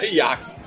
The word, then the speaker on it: Ajax: It must be, because that's, they're in Amsterdam, so that must be the Dutch pronunciation of Ajax. Yeah. – Oh, yeah. Yeah. – Yax.